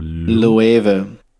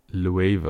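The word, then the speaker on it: Loewe: Is it Le or Louave? – Louave, okay. This is the latest collab with Louave. I didn't even know it was pronounced like that.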